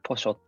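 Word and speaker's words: pollo: ポショってなりますね。チリ、アルゼンチンとかは。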